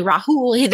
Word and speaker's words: Rahul: Rahul, I think is how you pronounce it. – Rahul Chopra. – Yes. – Yeah, it is actually Rahul.